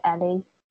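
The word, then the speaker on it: Elle: Okay, yeah, that's how you say her name. It's spelled L, but I'm pretty sure she pronounces it Ellie.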